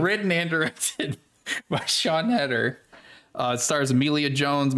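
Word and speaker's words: Siân: But anyway, the film was written and directed by Sean Hedder, which the only reason I know how to say this name is because of Taskmaster, believe it or not. S-I-A-N. Her name is Sean.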